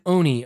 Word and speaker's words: Oni: It is an Oni, O-N-I, pronounced O-ni, O-ni.